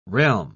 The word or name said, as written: realm